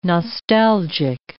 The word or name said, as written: nostalgic